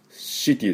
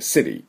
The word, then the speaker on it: city: city じゃなくて city。でも citizen、 citizen っていうふうに発音しなければいけません。ね、最初の音、す、す。ね、どちらかというと、すの音です。